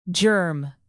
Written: germ

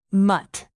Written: mutt

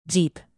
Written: jeep

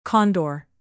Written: condor

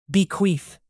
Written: bequeath

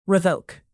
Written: revoke

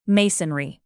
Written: masonry

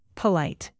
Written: polite

